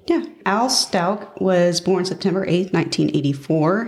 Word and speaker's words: Stauch: So it's spelt like Stauch. Like I thought it was Stauch, but it's Stauch is how it's pronounced.